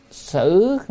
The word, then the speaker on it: sử: Chữ này có hai âm, một là sứ hay là sử. Nếu mà đọc là sứ thì là nơi chốn, còn ở đây đọc là sử tức là ở.